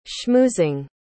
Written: schmoozing

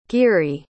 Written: geary

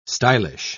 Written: stylish

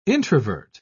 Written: introvert

